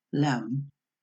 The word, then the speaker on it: lamb: A veces la letra B es muda, lo que en inglés se llama silent B, y que ocurre en palabras que terminan en las letras MB. Entonces se pronuncia solamente la M y no la B final, como es el caso de las palabras lamb, cordero, comb, peineta, o climb, escalar.